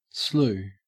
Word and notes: slew: Australian English

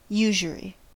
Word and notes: usury: American English

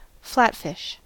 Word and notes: flatfish: American English

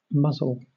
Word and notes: muzzle: British English

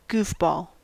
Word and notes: goofball: American English

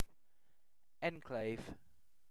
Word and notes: enclave: British English

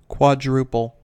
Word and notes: quadruple: American English

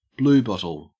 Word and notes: bluebottle: Australian English

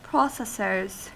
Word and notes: processors: American English

